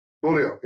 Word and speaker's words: Beaulieu: But thank you so much for hanging out and kind of giving us a little glimpse into who Gator Beaulieu really is. Did I say your name right? The last name? – Gator Beaulieu. – Beaulieu, yeah.